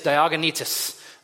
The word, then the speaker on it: Diognetus: And what is the guy's name? I want to pronounce it right. The guy's name is Diognetus.